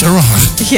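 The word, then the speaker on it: Erewhon: It's Erwahan. – Yes, it's Erwahan.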